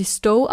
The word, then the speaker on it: Stowe: Danach hat sie noch bei einer Informationshotline angerufen, um Infos über Hotelbuchung in der Stadt, ja, Stowe, wahrscheinlich spricht man sie Stowe aus. – Ich würde sie auch Stowe aussprechen.